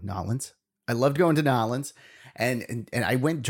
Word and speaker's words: New Orleans: Uh, it refers to the events of the Carnival celebration in Nolens. That's how they say it there. They don't say New Orleans. They say Nolens.